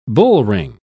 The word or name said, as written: bullring